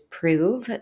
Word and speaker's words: Proov: So the name of the company is Prove. So it's spelled P-R-O-O-V.